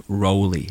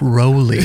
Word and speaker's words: Rowley: Okay, it's Geoff Rowley for Geoff the record. – Rowley. – Geoff Rowley. Yeah. Like Roly Poly.